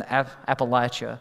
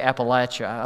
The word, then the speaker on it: Appalachia: When I was still a student in Bible college, I was asked to preach at this small little mountain church in Appalachia. Now, if you're from there, you say Appalachia. Around here, people say Appalachia, I've noticed. I don't care how you say it, but people from there, they say Appalachia.